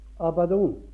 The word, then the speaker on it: Abaddon: Er det riktig uttalt det? Abaddon? Jeg er ikke for stor til å spørre. Abaddon. Det er hebraisk, ja.